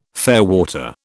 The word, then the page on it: fairwater: British English